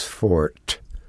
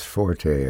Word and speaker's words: forte: There's laughter and grinning, and I see him occasionally that way, but humor is not his fort. And someone will write in and say it's pronounced forte, and they're wrong.